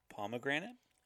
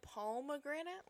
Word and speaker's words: pomegranate: How did you say that word? – Pomegranate. – Okay, I thought you said pomegranate, like the palm of your hand, and I was like, that's not right.